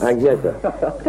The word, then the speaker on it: Agneta: Agneta.